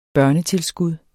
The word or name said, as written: børnetilskud